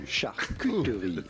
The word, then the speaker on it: charcuterie: You do want to insist on the Q part, and it's Q, not coup, because yes, it is a French word and a name of French origin, and the French pronounce the U, charcuterie. Charcuterie. Now in French, you'd say charcuterie. Charcuterie. Charcuterie. So in English, charcuterie.